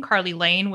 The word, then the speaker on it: Carly Lane: It's spelled C-E-I-L-I-D-H-A-N-N. – You can find me on Twitter at my name, Carly Lane, which is spelled C-A-R-L-Y-L-A-N-E.